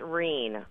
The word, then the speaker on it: Wren: And uh, Jody, first of all, you say Wren, you don't say like the French, like, is that right? – Well, it's actually pronounced Reen, like green without the G.